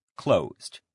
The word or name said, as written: closed